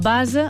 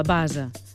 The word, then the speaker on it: base: El base o la base, pronunciat amb essa sonora i a neutre.